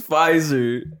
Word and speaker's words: Pfizer: It's Pfizer. The P is silent.